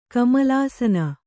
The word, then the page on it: ಕಮಲಾಸನ: kamalāsana